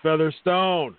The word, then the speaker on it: Featherstone: You said Weatherspoon, and it's Featherstone.